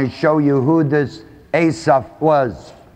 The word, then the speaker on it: Asaph: One of those seven is a man by the name of Asaph. It's spelled A-S-A-P-H.